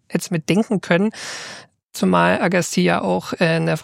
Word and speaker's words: Agassiz: Er schreibt: Kleines Nitpicking, der Name von Louis Agassiz, wie ich es in der Folge gesagt habe, den spricht man ohne S am Ende. Also ich habe Agassiz gesagt, weil es schreibt sich, der Nachname schreibt sich mit einem Z am Ende. Aber dieses Z spricht man nicht, also eher Agassiz.